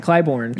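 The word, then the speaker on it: Clybourn: And it was brewed in honor of the 1,000th batch of Goose Island's original Slyborn Brew Pub, I guess. How do you pronounce that? Clybourne. – Yeah.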